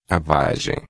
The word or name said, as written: vagem